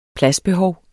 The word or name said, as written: pladsbehov